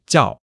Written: jiao4

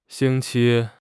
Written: xing1 qi1

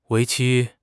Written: 为期

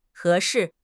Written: he2 shi4

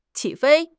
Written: qi3 fei1

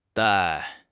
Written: dai4

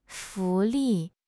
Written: 福利

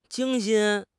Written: 精心

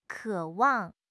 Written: 渴望

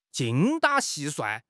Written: 精打细算

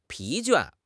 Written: 疲倦

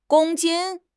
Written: gong1 jin1

